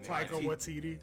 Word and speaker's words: Taika Waititi: It needed that fucking Sean the Arm from basically Waika to I don't know how to say it. – Taika Waititi.